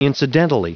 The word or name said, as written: incidentally